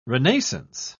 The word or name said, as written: renaissance